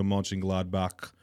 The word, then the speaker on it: Mönchengladbach: This Vasquez guy is being linked to Borussia Mönchengladbach.